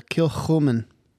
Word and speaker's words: Kilchoman: That is a Kilchoman.